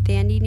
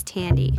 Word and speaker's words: Thandie: There's been a lot of talk on other podcasts that I've heard many people pronouncing the actress's name as Thandie Newton, mm-hmm. and that's why I pronounced it that way. But somebody said the other day it's Tandy.